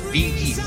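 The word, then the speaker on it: Bee Gees: No que sea que estemos viejos, que ahora que está agosto, no, de los BG se escribe, pero se pronuncia Biggie.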